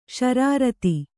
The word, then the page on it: ಷರಾರತಿ: ṣarārati; kannada